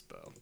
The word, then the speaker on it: Beau: Is it Beau or Bo? – Man, I don't know anymore. So sorry. – God, okay. I should never trust your pronunciation of anything, so I'm going to go check that out later. – Oh, it's Bo.